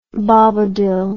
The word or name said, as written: Bobadill